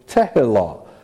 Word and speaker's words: tehillah: And by the way, this isn't necessarily the way you spell tehillah in Hebrew, but that's the way you pronounce it. So every time I write a Hebrew word, I'm just writing phonetically how you pronounce it.